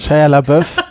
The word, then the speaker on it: Shia LaBeouf: Ich habe äh, die Karriere von Shia Beleuf, äh, Shia Buff, LaBeouf. Shia LaBeouf. – Er selbst nennt sich ja übrigens Shia LaBeouf, weil Amerikaner können dieses Französisch ähm, nicht aussprechen.